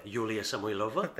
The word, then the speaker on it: Yulia Samoilova: Yulia Samoilova.